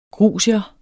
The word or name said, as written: grusier